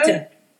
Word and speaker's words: Kuta: I'm going to say it wrong. The Kuta. Is it Kuta? – Kuta.